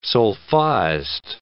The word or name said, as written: sol-faist